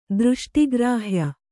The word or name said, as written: ದೃಷ್ಟಿಗ್ರಾಹ್ಯ